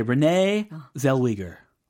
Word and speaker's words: Renee Zellweger: Okay, Renee Zellweger. Renee Zellweger. – 귀를 의심하고 계십니까? – Renee Zellweger. It's so different. – Oh, Renee Zellweger. right? Is that right? Renee Zellweger.